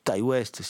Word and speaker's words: Ti West: Depuis qu'un cas, l'ami euh, Ti west Ty West, si on le prononce euh, comment dire, avec l'accent qui va bien, euh, a réalisé pas mal de films, on en a parlé régulièrement dans, dans l'émission.